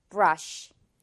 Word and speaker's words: brush: 마지막에 마무리를 이 식 조용히의 발음으로 처리해주면 돼요. One more time. brush.